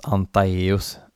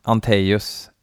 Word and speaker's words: Antaeus: Eh, Ante, det kan ju inte uttalas Antaeus. Ante, an, Anteus. Anteus. Så det är grekiska, tror jag.